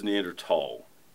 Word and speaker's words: Neanderthal: It is Neanderthal. Even with the H in there, the H is silent in German.